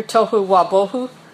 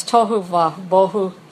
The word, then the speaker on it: tohu wabohu: The terms empty and void, or empty and waste, have an interesting pronunciation in Hebrew, tohu wabohu. It's a rhyming couplet. One of those rare things in Hebrew. Hebrew hardly ever has rhyming words. It has tohu wabohu.